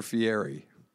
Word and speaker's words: Fieri: What's his name? Fieri or Fieri? – Fieri. It's spelled Fieri. It's Fieri.